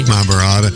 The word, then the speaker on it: Mahabharata: And he revered all the gods, and Krishna was How about the Mahabharata? Do I say that correctly? – Mahabharata.